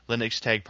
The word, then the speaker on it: Linux Tag: Linux Tag participants. I'm pronouncing it incorrectly. It's Linux Tag, like Linux Day, but it's in German, so Linux Tag is the correct pronunciation.